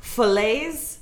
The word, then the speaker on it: Falaise: F-A-L-A-I-S-E. – Falaise. – Falaise. – Falaise. Falaise. – Falaise. – Since it has the E on the end, you should pronounce the S, so it should be really? – Falaise, probably.